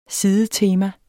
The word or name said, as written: sidetema